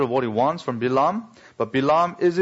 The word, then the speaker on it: Bilaam: Incidentally, the Hebrew pronunciation is Bilaam, not Balaam.